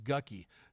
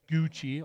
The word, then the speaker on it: Gucci: It looks like it should be pronounced Gucci to me, but it Gucci, okay, fine.